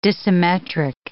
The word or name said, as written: dissymmetric